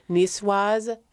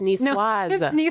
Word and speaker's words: niçoise: But niçoise, framboise, and cerise. – Because you say the S.